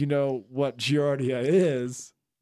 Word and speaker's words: Giardia: It's called Giardia.